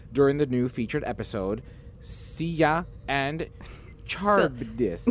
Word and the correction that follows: Scylla: Scylla and Charybdis.